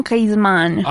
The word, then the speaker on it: Griezmann: Es el penal que termina errando el señor Griezmann o Griezmann, ¿cómo se dice? – Antoine Griezmann.